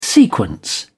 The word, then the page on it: sequence: British English